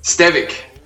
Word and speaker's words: Stevic: Now, is it Stevic? Stevic? How do we pronounce the E in your name? – Stevic.